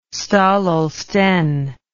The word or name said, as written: Staël-Holstein